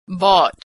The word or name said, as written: bought